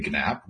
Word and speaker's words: GNAP: I personally say GNAP with a hard G, but there is no official pronunciation.